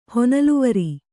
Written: ಹೊನಲುವರಿ

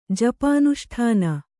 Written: ಜಪಾನುಷ್ಠಾನ

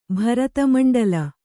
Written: ಭರತಮಂಡಲ

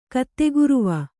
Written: ಕತ್ತೆಗುರುವ

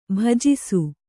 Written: ಭುಜಿಸು

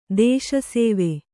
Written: ದೇಶಸೇವೆ

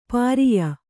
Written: ಪಾರಿಯ

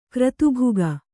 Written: ಕ್ರತುಭುಗ

